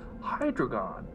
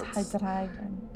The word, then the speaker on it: Hydreigon: Z-W-E-I-L-O-U-S. – Yeah. Zwei. Ein, zwei, drei. – Hydreigon. – Hydreigon. – Hydreigon. When you hear people using it in VGC, the commentators always say, Hydreigon. – I'm like, no, it's Hydreigon.